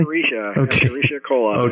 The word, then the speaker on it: Escherichia coli: Is it Escherichia coli? – Escherichia coli.